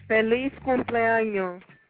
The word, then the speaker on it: Feliz cumpleaños: Feliz cumpleaños.